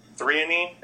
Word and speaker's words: threonine: Threonine, is that how you say that one? – Mm-hmm. Yeah.